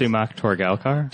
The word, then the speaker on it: Sumukh Torgalkar: Sumukh Torgalkar? – Turns out.